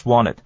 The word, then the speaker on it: wanted: Just 那个 T 啊，后面因为是 w 的声音呢，所以那个 T 不发音了，所以 just wanted 我们讲 just wanted。 Wanted 本身念快的时候，那个 T 本身不太发音的，所以我们是念做 wanted, wanted。